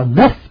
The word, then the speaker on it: neuf: Số 9 thì là tiếng Pháp đọc là neuf, tiếng La Mã đọc là nove, novo, là N-O-V.